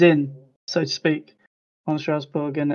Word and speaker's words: Strasbourg: I've listened to podcasts, and apparently we got to say it in a more, way more French way. – Strasbourg.